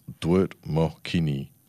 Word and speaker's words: dwert mokini: And the Noongar word of the day is dwert mokini, in English dingo, pronounced dwert mokini.